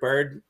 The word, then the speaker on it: Byrd: I'm gonna go Rick Beard uh, Rick Byrd. How do you say it, Rick Beard? – Bird? Rick Rick Rick Byrd,